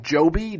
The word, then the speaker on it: Joby: It's called, I think it's pronounced Joby, J-O-B-Y.